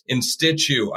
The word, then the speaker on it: InStitchU: It's InStitch. So it's N-N-I-N, like in and out, and then Stitch, and then the letter U.